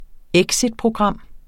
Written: exitprogram

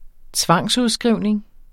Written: tvangsudskrivning

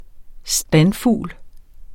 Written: standfugl

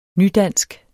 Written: nydansk

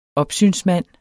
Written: opsynsmand

